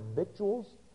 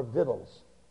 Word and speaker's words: victuals: You know, that's actually a word that the correct pronunciation, you can correctly pronounce that word victuals, like it sounds out, or victuals. Actually, either pronunciation is an acceptable pronunciation.